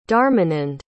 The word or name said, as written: dharmanand